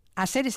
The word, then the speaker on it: Asere: Asere, ¿se pronuncia así? – Asere.